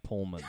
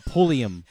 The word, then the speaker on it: Pulliam: It's Keisha Knight Pullman. Pulliam.